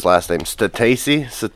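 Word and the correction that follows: Stasi: She's 19. – Stassi.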